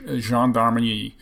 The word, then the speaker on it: gendarmerie: And there's also the French are doing this as well in their the French gendarmerie. I probably pronounced that wrong. – Gendarmerie, yeah.